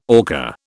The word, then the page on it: orca: British English